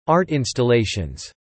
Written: art installations

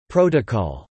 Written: protocol

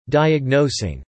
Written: diagnosing

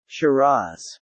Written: shiraz

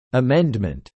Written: Amendment

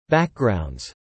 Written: backgrounds

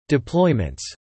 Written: deployments

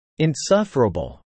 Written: insufferable

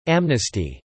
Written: amnesty

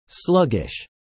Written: sluggish

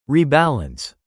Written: rebalance

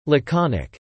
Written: laconic

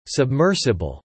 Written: submersible

